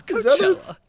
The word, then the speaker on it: Coachella: You say Coachella. – Yeah, is that a festival? – It's Coachella. – Is it Coachella? – Ass.